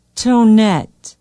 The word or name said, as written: tonet